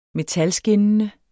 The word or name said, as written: metalskinnende